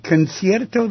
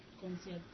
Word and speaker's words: Concierto: Okay, so it's spelled Concierto, C-O-N-C-I-E-R-T-O, de Aranjuez, A-R-A-N-J-U-E-Z. Let me see, Concierto. Let me ask somebody from Honduras. Is Concierto the way you say concerto in Spanish? Makes sense, doesn't it? – Yes. – How would you pronounce it? – Concierto. – Concierto, good. We have our Honduras specialist here. Today, Concierto de Aranjuez, A-R-A-N-J-U-E-Z, and there are a few forms of it played with guitar and orchestra, and I have just heard some amazing guitar pieces.